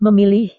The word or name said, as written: memilih